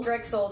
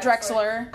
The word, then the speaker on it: Drexel: And then they beat Drexel. – Drexel, not Drexler.